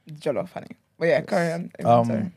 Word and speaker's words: jollof: It's not J-E. I swear when people say jollof. – Jollof. – It's jollof. J-O-L-L-O-F.